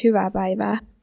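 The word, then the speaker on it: hyvää päivää: Iva Paiva, it's hello. Incredible. Nemenion Albon. – Actually, it's Hyvä Paiva.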